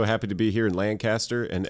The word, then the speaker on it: Lancaster: Give me a point for this because you mispronounced the city. It's Lancaster, not Lancaster.